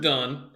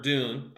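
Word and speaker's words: Dunne: Richard Dunn, or Dune, I don't know how to pronounce his name. – Yeah, you got it right the first time.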